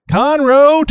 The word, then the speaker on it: Conroe: Conroe, Texas.